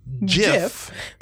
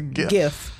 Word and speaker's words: GIF: Hard G, soft G. We gonna get into that argument? – We can't. – Technically, she's right. It is Jif. – But everybody wants to call it a GIF.